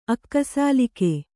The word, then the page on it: ಅಕ್ಕಸಾಲಿಗ: akkasāliga